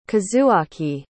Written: kazuaki

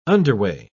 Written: underway

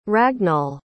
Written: ragnall

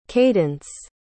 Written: caydence

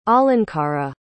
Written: alankara

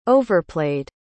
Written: overplaid

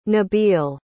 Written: nabil

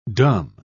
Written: dumb

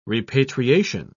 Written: repatriation

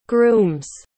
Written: groom's

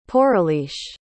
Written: poureliche

